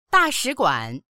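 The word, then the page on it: da4shi3guan3: Mandarin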